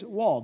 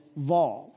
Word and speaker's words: Waw: So tonight, we pick up this sixth letter, and it is Waw, W-A-W. And I think even I've heard it pronounced as Vaw with a V.